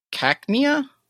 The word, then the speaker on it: Cacnea: November 2nd is Cacnea.